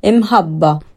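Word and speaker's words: imħabba: Imhabba, imhabba, imhabba. – Avete capito? Imhabba.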